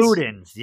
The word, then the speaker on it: Luden's: Am I saying it right? Luden's? – Luden's, yes. Luden's.